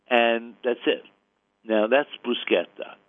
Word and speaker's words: bruschetta: Now, I know it's mispronounced even on TV, which really irks me, that I feel like a TV announcer, especially one doing maybe an Italian travelogue, should know how to pronounce the word. But you hear the mispronounced bruschetta all the time. It is bruschetta.